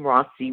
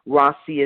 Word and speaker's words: Rawsi: Finally, you can connect with me on social media using my name, Rossi Williams, for Facebook, Twitter, and Instagram. Rossi is spelled R-A-W-S-I, so that's Rossi Williams.